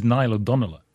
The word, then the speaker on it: Niall O'Donnell: His name, by the way, has been pronounced different ways on every single programme, but I think it's Niall O'Donnell, isn't that correct? – Okay.